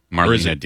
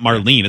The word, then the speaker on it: Marlene: Marlena Dietrich. – It's not Marlene. It's spelled Marlene.